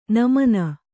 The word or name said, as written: ನಮನ